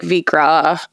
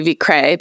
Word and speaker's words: Vikre: So we kind of go with like Vikre. A lot of people do Vikre because it sounds more European.